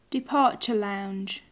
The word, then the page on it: departure lounge: English